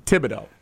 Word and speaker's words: Thibodeau: Thibodeau.